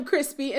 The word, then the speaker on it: Chrispy: And I am Crispy, and that's spelled C-H-R-I-S-P-Y.